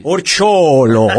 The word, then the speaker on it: Orciollo: Orciolo, Orciolo, caspita.